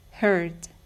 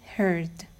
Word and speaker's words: heard: Present tense, hear. Past tense, heard. Present tense, overhear. Past tense, overheard. در زمان حال ساده hear تلفظ میشه و گذشتش میشه heard. در زمان حال ساده overhear تلفظ میشه و گذشتش میشه overheard.